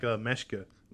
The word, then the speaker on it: Meschke: It's supposed to be like Meschke. That's how you say it.